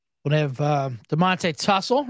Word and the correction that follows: Tuggle: right? Running back from Ohio. Mm-hmm. – Tuggle, right? – Tuggle. Yep. Tuggle. I tussle with my pronunciations a lot and reading my handwriting.